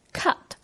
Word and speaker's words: cut: 这个英语单词 cut 原来的发音是 cut。而当它夹在这句粤语中使用时，变成了类似粤语咳嗽的“ 咳” 的发音，cut，cut。